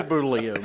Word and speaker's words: Bollea: It's Terry Bollea, man. Come on. – Bollea? – Bollea. – That sounds weird. – It's spelled weird. – Yeah, it's spelled weird.